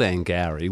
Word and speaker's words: Angourie: called Angowrie, which is spelt differently, A-N-G-O-U-R-I-E, up near Yamba on the north coast of New South Wales.